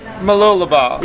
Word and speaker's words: Mooloolaba: Mooloolaba.